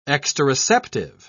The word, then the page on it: exteroceptive: British English